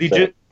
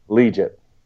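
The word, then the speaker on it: Liuget: Legit. – Legit.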